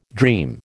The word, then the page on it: dream: American English